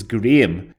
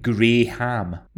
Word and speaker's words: Graham: Apparently because it sounds like Graham. No, it doesn't. It sounds like Graham. It's spelled G-R-A-H-A-M. So I suppose spelled, it looks a bit like Graham. But you don't pronounce it that way.